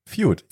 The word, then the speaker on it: Feud: Feud.